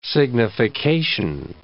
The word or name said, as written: signification